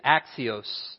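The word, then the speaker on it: axios (ἄξιος): The word worthy in the Greek is axios. And it sounds like the word axis where we get our word.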